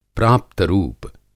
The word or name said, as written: प्राप्त रूप